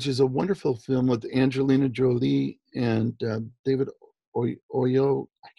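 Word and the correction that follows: Oyelowo: Yellowo? – Yes.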